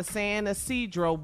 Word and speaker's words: San Isidro: How do you say this? Isidro? Is that it? – San Isidro. – He attempted to cross the San Isidro border crossing and was pulled out of his vehicle for additional inspection.